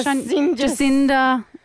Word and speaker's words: Jacinda: How do you pronounce her name? Jacadia? – No, I wish Jacinda.